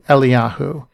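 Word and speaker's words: Eliyahu (אליהו): Elijah is pronounced in Hebrew, Eliyahu.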